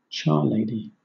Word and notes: charlady: British English

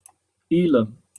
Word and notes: ylem: British English

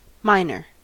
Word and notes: minor: American English

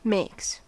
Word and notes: makes: American English

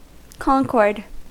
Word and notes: concord: American English

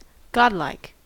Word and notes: godlike: American English

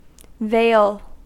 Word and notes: vale: American English

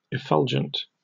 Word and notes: effulgent: British English

